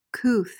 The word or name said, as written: couth